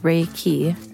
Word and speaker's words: Reiki: Translated as universal life force energy, Reiki, pronounced Reiki,